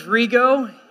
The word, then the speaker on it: Rigo: If I haven't met you yet, my name is Rigo. It rhymes with amigo.